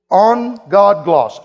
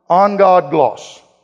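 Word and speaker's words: An Geadh-Glas: But the Celtic Christians of old used to have a term, and my best pronunciation is it on God gloss. I listened to Mark Batterson on YouTube just to make sure I was saying it right. On God gloss.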